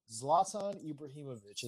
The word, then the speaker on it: Zlatan Ibrahimovic: Zoltan. Y'all seen dude wear Zoltan? Yeah, that's Zoltan. – Zoltan. Zlatan Ibrahimovic is his name.